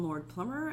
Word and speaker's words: Lord Plummer: My name is Kim Lord Plummer, and my last name is spelled LORDPLUMMER.